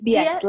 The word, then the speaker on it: Biet: Yes, it's Biet, like Juliet.